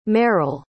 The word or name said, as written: merril